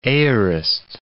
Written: aorist

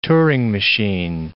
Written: Turing machine

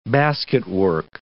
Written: basketwork

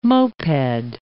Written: moped

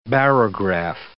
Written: barograph

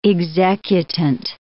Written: executant

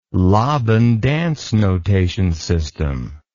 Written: laban dance notation system

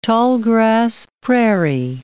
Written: tallgrass prairie

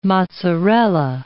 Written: mozzarella

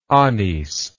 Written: anis

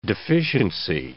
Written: deficiency

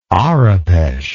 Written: Arapesh

